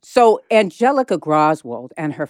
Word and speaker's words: Graswald: Graswald? – Yeah, it's spelled G-R-A-S-W-A-L-D. We think it's Graswald.